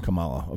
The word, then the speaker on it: Kamala: How are you pronouncing her name? Carmella. I'm trying to be disrespectful, but isn't it Carmella? I think it's Kamala. Is it? – Kamala. – Okay, sorry. Sorry, Kamala.